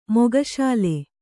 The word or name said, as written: ಮುಖಶಾಲೆ